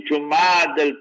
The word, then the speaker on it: Jumada: Remember the best way to pronounce this month, the month that's coming, is Jumad al. People, they write all funny, funny spellings in this. The word is Jumada.